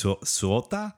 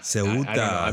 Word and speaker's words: Ceuta: So it's C-E-U-T-A. So it's Suota? – Sueta? – I don't know, man. – Ceuta. – Ceuta. Ceuta, right? Yeah, because C-E in Spanish is like an S, right?